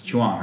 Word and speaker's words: Chuang: It's Chuang, right? – It's Chuang, one syllable. There are a lot of people who do Chuang, and that is incorrect.